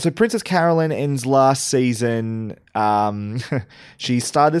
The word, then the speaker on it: Carolyn: Let's talk about Princess Carolyn first. And I'm saying Princess Carolyn because even though half the people say Princess Caroline, she says Princess Carolyn. So I'm going to go with that.